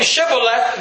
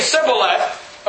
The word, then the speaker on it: Shibboleth: He's saying Shibboleth. They're saying Sibboleth.